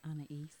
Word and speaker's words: Aeneas: Did I say that right? – Aeneas. – There you go.